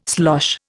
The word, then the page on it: slosh: Indian